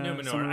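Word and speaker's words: Númenor: Yeah, is that in the Numenor.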